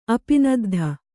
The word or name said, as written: ಅಪಿನದ್ಧ